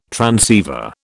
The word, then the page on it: transceiver: British English